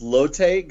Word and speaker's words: Lotte: I can also add in that it's pronounced Lotte. Going back to 30 minutes ago, it's pronounced Lotte Giants.